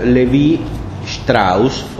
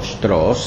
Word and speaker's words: Lévi-Strauss: Uno de los representantes, tal vez el más importante, que da inicio a esta corriente en el sentido más estricto, es Claude Lévi-Strauss, o Strauss, según se pronuncie de manera alemana o francesa.